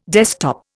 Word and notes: desktop: Indian

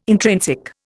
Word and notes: intrinsic: Indian

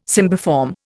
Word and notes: cymbiform: Indian

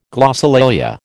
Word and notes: glossolalia: American English

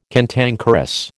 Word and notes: cantankerous: American English

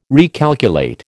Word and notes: recalculate: American English

